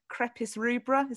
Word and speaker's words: Crepis rubra: And it's the little pink dandelion, that Crepis rubra, is that how you say it? – Yes.